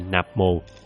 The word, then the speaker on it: Nam Mô: Chữ Nam Mô cả cõi đều đọc là Nạp Mồ, sao ông lại chiếu theo âm gốc để đọc?